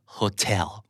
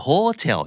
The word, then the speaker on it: hotel: hotel อ่านว่า hotel ไม่ hotel